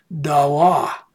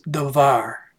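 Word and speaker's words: davar (דָּבָר): So it's D-A-B-A-R is how we would transliterate it, and this is it in the Hebrew. But here is the pronunciation of it, dawa. But Hebrew, no one knows how it was pronounced back in Jonah's day, and so this is a more modern pronunciation of the word. When I took Hebrew, I learned it as davar.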